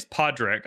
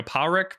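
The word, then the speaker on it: Pádraic: He plays Podrick. I'm just going to say Podrick. It's not how you say it. In the movie, it's like more of like a Powrick, but it just sounds weird in my accent.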